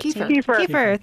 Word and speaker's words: kefir: K-E-F-I-R, help me out. – Kefir, thanks.